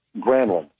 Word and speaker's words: Granlund: He came in the playoffs, and he changed the way Mikhail Granlund said his name, where he said, well, the actual Finnish pronunciation is more like Granlund. And I said, okay, but I asked the player, and the player said Granlund.